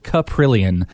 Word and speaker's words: Kaprielian: That is how we say it. Kaprielian.